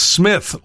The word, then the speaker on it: Smythe: This year's number one restaurant is Smith, located in Chicago. I hope I'm saying that right. I think I am. It's spelled like Smythe, S-M-Y-T-H-E. However, the thing that is kind of interesting to me, I got to talk to my wife about this.